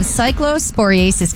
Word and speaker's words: cyclosporiasis: So dozens of cyclosporiasis cases are being tracked across Florida and the Tampa Bay area. – So that's how you say it. Say it one more time. – Cyclosporiasis. And I'm pretty sure that M part, I had to like section it out.